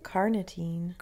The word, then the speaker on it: carnitine: But it's also really important for producing basically the energy your muscles use, called carnitine. That's probably not how you pronounce it. – Nope. – I'm putting way too many syllables in there.